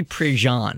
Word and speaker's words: Prejean: but this was based off of Carrie Prejean. Is that how you pronounce her name? – Mm-hmm. Mm-hmm.